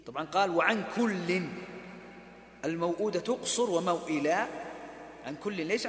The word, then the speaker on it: الموؤودة: على قصر الواو الأولى التي بعد في الموؤودة في سورة التكوير، وقصر واو موئلا في سورة الكهف، والمقصود بقصر الواو في هذه الكلمات الثلاث سوءات الموؤودة موئلا النطق بواو من غير مد مطلقة مثل النطق بواو فوق.